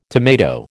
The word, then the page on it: tomato: American English